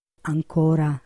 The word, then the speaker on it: ancora: La parola ancora può essere piana, con l'accento cioè sulla O, e indicare un avverbio di tempo, ancora adesso. Oppure può essere sdrucciola, con l'accento cioè sulla prima A, e indicare un sostantivo. L'ancora di una nave.